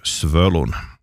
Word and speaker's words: Svölun: Och Svalan har ett ö, Svölun.